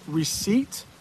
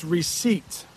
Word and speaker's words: receipt: Notice how I pronounce that, receipt. I think there's a silent P or something in there. It's receipt.